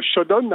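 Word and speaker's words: Showdown: Alors le Shodon, hein, le, N final se prononce,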